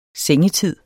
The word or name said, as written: sengetid